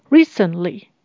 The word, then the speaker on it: recently: Recently，最近的，请留意这边的 t，它是念成 stop t，也就是把气给 hold 住，会比较好念一点。